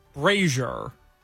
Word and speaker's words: brazier: Now, we got a text from April saying that Brazier is actually pronounced like Al Frazier. So it'd be like Brazier.